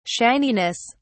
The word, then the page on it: shininess: British English